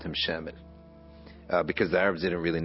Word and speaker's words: Shamil: And his name is actually Shamwil, but it just became pronounced Shamil.